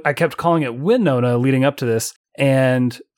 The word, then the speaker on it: Wynonna: Which they mispronounce, I'm fairly certain. Winona. Well, I think it's all up for interpretation because I would pronounce it Winona. It is Winona.